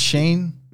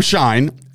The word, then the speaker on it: Schein: Is it Shane? – It's Adam Schein. I know that for a fact because I used to work at Sirius.